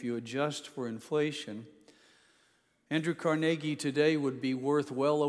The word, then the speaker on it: Carnegie: I've always heard this name pronounced Carnegie, but apparently in Scotland, where he's from, and in the area of Pennsylvania where their family settled, they pronounce it Carnegie.